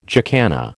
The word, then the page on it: jacana: American English